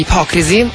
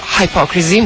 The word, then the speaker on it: Hypocrisy: Pain, allora, progetto di Peter Tetgren, cognome difficilissimo da pronunciare, comunque grandissimo personaggio già all'interno degli Hypocrisy, o Hypocrisy, un po' come volete, questa Zombie Slam.